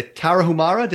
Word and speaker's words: Tarahumara: You go to cover a pop star, and you hear about this native tribe, the Tarahumara.